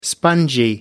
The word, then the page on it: spongy: American English